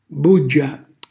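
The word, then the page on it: buggia: Calabrian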